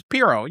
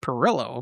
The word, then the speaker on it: Pirro: P-I-R-R-O. – Yeah, okay. Jeannie Pirro. That's what I thought. It was Pirro. Yeah, not Perillo.